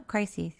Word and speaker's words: crises: Is that how you say it? Or do you say crises? No, crises. – I say crises. – Okay, I'll stick with crises.